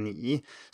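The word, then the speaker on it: ni (你): Like we don't say ni the same way, we have this dipping tone ni. Sometimes it's a rising tone, as in ni hao.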